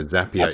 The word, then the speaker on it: Zapier: Although previous to speaking to you, I think I was calling it Zapier. As you pointed out to me, it sounds like happier. So Zapier the correct pronunciation.